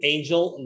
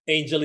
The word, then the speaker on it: Angel: Angel, that last name, Ribo. Ribo is spelled R-I-B as in boy, O. Angel is like an angel from heaven, A-N-G-E-L, right?